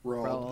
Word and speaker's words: Roald: I know, I always thought it was Ronald, and I was like, oh, crap, until, like, maybe 2 years ago. Yeah. I was like, oh, wow, his name is Roald, or how do you say it? – Yeah. – Roald, that's okay. You're right.